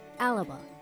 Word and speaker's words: Alba: While spelled A-L-B-A, it is pronounced Alaba, with an extra A in the middle, and the B has a softer, almost P sound, one that I haven't perfected yet, obviously. So that is Imagine Alaba, I-M-A-G-I-N-E-A-L-B-A.